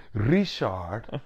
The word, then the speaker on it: Richard: Richard. Richard. You do not pronounce the D.